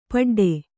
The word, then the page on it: ಪಟ್ಟಾಡು: paṭṭāḍu